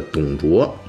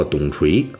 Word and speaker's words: 董卓: 关于老董的这第三期，咱们讲的就是曾经少年任侠、中年狂飙的董卓又是怎么玩完的，他为什么玩完了？在这儿啊，咱们先提一句啊，就是这个大锤啊，在前两期里边出现了一个错误啊，那个时候舌头一秃噜，就把这个董卓有一处念成了董锤啊，结果让不少粉丝啊、听友们都听出来了，就给我指出来了啊，这个确实是一个很低级的错误。